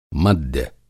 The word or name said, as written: मद्य